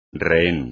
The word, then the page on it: rehén: espagnol